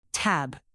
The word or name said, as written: tab